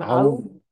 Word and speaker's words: Aoun: Aoun. – Aoun. Oh, okay. Aoun. Okay, I got it. Yeah. Aoun. It yeah. sounds like Aoun, yeah. which is like a yoga thing. – Oh, no, it's Aoun.